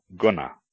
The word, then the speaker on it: guna: G, ga, is always a hard G, as in God, never a soft G, as in gym. For example, guna, quality, and yuga, age.